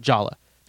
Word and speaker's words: Yala: Did some digging on the pronunciation of Yala, and the creators of Yala are actually okay with people calling it Jala. – Because, oh, really? – Yeah, right. – and they understand that that's the Western pronunciation of it.